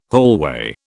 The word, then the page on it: hallway: British English